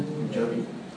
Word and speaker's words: Punjab: Punjabi. – Punjabi. Punjabi. Punjabi. No es pum. Es pam, pam, pam, pam.